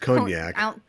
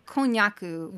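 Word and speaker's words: konnyaku: And again, I don't think it's pronounced cognac. – Konyaku, which is the Japanese term.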